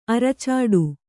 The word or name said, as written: ಅರಚಾಡು